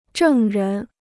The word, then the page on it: 证人: zhèng rén